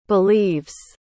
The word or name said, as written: believes